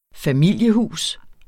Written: familiehus

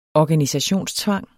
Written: organisationstvang

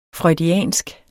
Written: freudiansk

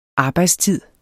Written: arbejdstid

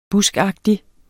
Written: buskagtig

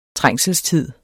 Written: trængselstid

